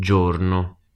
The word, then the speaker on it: giorno: Here the first two letters of giorno are G plus I. So again we get the soft J sound.